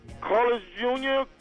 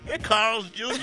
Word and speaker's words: Carl's Jr.: I don't know if I'm pronouncing it right. Carlos Jr., Carlos Jr., Carlos Jr. Carl's Jr.? – Oh, yeah, that one.